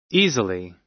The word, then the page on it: easily: American English